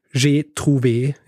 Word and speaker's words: Trouvé: Sein Markenname, der ab jetzt auf allen Gerätschaften eingraviert sein wird, lautet G. Trouvé, das französisch ausgesprochen J'ai trouvé ist.